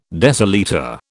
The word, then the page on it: decilitre: British English